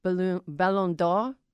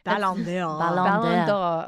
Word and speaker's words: Ballon d'Or: Our producer Caroline, who speaks French, told me the pronunciation is Ballon d'Or. Oh, I did it. – Ballon d'Or award recognizing.